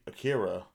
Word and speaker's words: Akira: And turns out this anime Akira, I'm sure it's pronounced completely different. – Nope, that's you said it correct.